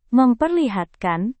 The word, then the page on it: memperlihatkan: Indonesian